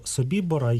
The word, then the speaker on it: Собібор: Ну, власне, я певно з цим погоджуся, але у випадку Собібора, я наголошую, що я вживаю такий наголос через те, що цей табір розташовувався на території Польщі, і поряд із ним знаходиться польське село Собібор. яке за правилами польської мови має наголос саме на голосний «і».